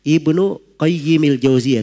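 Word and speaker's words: Ibnu Qayyimil Jauziyah (ابن قيم الجوزية): Kalau mau disebut Al-Jauziyahnya dibacanya Ibnu Qayyimil Jauziyah, tidak pakai alif lam pada al-Qayyimnya. Tidak pakai alif lam, tapi dikatakan Ibnu Qayyimil Jauziyah. Kalau mau dipakai alif lam, dikatakan Ibnul Qayyim saya. Jangan dikatakan Ibnul Qayyimil Jauziyah. Itu keliru ya cara bacanya, keliru.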